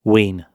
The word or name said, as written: wean